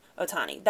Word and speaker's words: Ohtani: Ohtani.